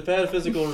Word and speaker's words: pataphysical: Is this the panthetical review, or... I'm saying it wrong.